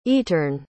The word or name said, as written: eterne